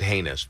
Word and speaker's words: heinous: And if you're and you're making fun of the most heinous stuff, so that's, I said heinous, by the way. – Right. – What do you want, applause? – No, no. It rhymes with a word. It's not toast. – You can say anus.